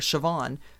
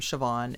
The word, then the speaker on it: Siobhan: But it's spelled S I O B H A N. But if you go to some place like Starbucks and give them your actual name, if it is Siobhan, they're going to spell it C H E V O N N E or C H E V O N or a variety of spellings.